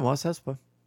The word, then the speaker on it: Mos Espa: Am I saying that right? – Yeah, Mos Espa.